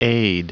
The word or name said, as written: aide